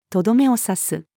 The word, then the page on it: とどめを刺す: female